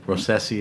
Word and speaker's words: Rossese: So if we stay in that same region, actually, there's a grape variety called Rosese. – Spell it. – R-O-S-S-E-S-E. – Okay. Rosese is the grape? – Yes.